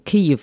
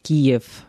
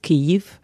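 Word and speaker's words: Kyjiw: Viele sind aus Kiew, weil richtig spricht man Kiew, nicht Kiew aus. Kiew ist auf Russisch, auf Ukrainisch ist Kiew.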